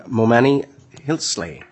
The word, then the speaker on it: Momani Hilsley: Representative Momani Hilsley. Did I say that right? – Yes, you did. Thank Fantastic. – You. – Fantastic. – It's like more money, Momani.